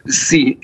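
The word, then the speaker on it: si (四): Parce que 4, ça, je vais mal le prononcer, mais ça se prononce si.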